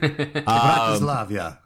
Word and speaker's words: Bratislava: Bratislava.